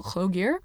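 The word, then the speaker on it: Lloegr: I did date a Welshman for 5 years, so you'd think I'd be able to, but it's Hlogir, so the double L is pronounced like with a H, as far as I recall, Hlogir, which is of uncertain origin, and it indicates this area specifically, like what is now England.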